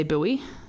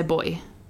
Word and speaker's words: buoy: I'll also say here, I looked up the pronunciation of boy, and in America they say buoy. – Oh, what do we say here? – We say boy.